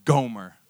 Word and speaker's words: Gomer: This girl's name was Gomer. It rhymes with Homer.